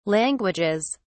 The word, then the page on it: languages: British English